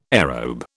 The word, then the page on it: aerobe: British English